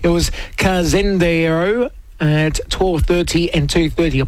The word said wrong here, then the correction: Cazendero: I butchered that. It's Cazendero, I should say, the 7 at Casino paying on 5, 4, 3 and 1.